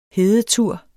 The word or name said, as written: hedetur